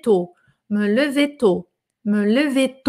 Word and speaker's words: lever: Atenção na pronúncia desse lever aqui, que esse E aqui é meio morto, lever.